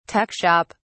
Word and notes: tuck shop: British English

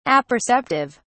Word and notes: apperceptive: British English

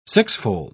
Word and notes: sixfold: British English

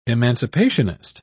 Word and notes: emancipationist: British English

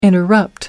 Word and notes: interrupt: American English

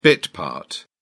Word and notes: bit part: British English

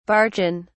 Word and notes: barchan: British English

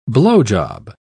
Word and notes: blowjob: British English

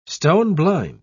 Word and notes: stone-blind: British English

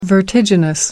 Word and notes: vertiginous: American English

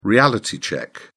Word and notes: reality check: British English

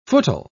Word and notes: footle: British English

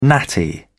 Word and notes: natty: British English

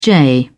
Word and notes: jay: British English

